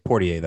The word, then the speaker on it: Poirier: Poirier, that's it.